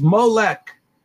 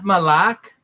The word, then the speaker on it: Molech: It's identical spelling, but 4432 is Molech, not Malak or Melech, but Molech, Molech.